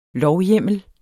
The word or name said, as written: lovhjemmel